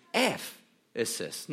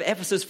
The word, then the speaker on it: Ephesus: Now I say it, it sounds silly, but the correct pronunciation is Ephesus, not Ephesus, but Ephesus.